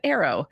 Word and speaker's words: Airo: So in making those videos, I needed to pronounce the full name of the product. And I had only said Aero, A-I-R-O. And so I used AI to go back and fix those pronunciations.